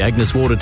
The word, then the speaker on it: Agnes Water: Heading down to Agnes Waters. – Right. It's pronounced Agnes Water.